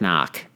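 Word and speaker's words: Cnoc: But anyway, so there's an aftermarket bag called, I think you pronounce it Knock.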